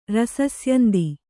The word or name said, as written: ರಸಸ್ಯಂದಿ